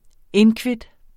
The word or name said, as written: inkvit